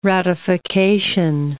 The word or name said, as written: ratification